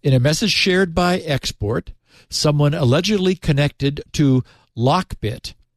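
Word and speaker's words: 3XP0RT: Now, we would pronounce his name export, his handle export, but it's, you know, numeral 3 XP numeral 0 RT. So, you know, in leet speak.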